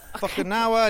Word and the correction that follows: Fukunaga: I never get his name right. The guy that brought us True Detective season one, all the episodes, you know, just. Cary Joji Fukunaga, yeah.